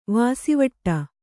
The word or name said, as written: ವಾಸಿವಟ್ಟ